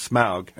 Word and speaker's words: Smaug: The sequence with the barrels, and, of course, the sequence with Smaug, and thank you for pronouncing it correctly.